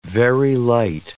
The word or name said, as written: Very light